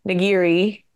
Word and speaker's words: nigiri: We got quick serve sushi and nigiri.